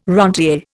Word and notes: rentier: Indian

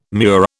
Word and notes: murex: British English

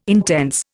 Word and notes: intense: Indian

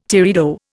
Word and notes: teredo: Indian

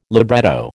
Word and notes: libretto: American English

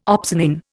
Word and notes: opsonin: Indian

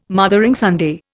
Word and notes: Mothering Sunday: Indian

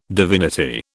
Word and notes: divinity: British English

